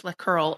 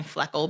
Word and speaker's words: fleckerl: Unlike the natural and reverse turns, the fleckerl, which is pronounced fleckerl, although it sound I thought Len was saying fleckle because he's British, does not move forward along the dance floor, but instead rotates on the spot.